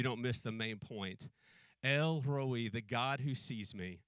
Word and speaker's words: El Roi: And that name is El Roi. Sometimes it's pronounced Elroy, but we have a human name, Elroy. So we're gonna pronounce like the Hebrews pronounced it, Elroi.